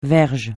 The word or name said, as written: verge